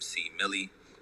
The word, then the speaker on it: C. Millie: C. Millie is three syllables. – But you know, people be like C. Millie. People like M-I-L-L-I-E. I'm like, no, it's like...